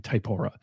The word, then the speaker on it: Typora: I always get hung up on pronunciation. I'll say it again. Typora.